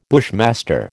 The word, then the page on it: bushmaster: American English